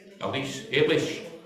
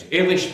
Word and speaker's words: Eilish: I think it's Elish McColgan. Is it Eilish? Eilish. There's always somebody, I'll tell you the right name. I never get the right names. Eilish McColgan.